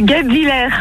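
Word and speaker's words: Guebwiller: On y est presque, la prononciation est pas ouf. – Vous avez raison. Je sais pas parler. Comment qu'on prononce? – C'est Gab Villers.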